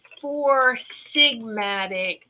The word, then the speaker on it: Four Sigmatic: I'm going to put it in the chat too. It's four sigmatic.